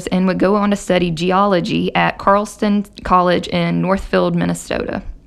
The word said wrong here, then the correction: Carleton: I think that's pronounced Carleton College, not Carl's.